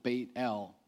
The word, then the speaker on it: Bethel: Name Bethel, they would have pronounced it Bait-El.